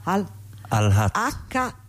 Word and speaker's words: Al-Haq: Al-Haq, Al-Haq.